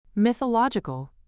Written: mythological

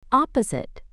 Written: opposite

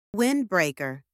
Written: windbreaker

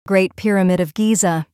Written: Great Pyramid of Giza

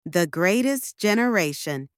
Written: The Greatest Generation

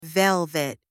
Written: velvet

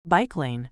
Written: bike lane

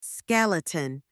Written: skeleton

